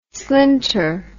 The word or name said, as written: slinter